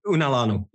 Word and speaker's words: Unalanu: So I think there's a story here behind the name, and I'm not sure if I'm saying this right, Unalanu. – Unalanu, yeah.